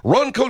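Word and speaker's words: Ranko: It's spelled R-A-N-K-O. R-A-N-K-O, but pronounced Ronco steel.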